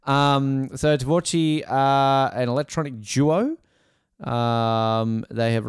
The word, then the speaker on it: Tvorchi: Is it Tvorchi? Tvorchi, am I saying that correctly, Jarrod? Or? – Yep, that's it.